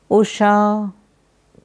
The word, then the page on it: Usha: Sanskrit